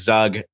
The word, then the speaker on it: Zug: And they very adamantly said, "It is Zug. There's no questions about it.